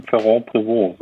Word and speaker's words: Ferrand-Prévot: Ferrand-Prévot, so sag ich, so spreche ich die zumindest aus.